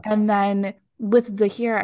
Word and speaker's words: Zahir: Is it Zahir, the guy with the poison? – That's right.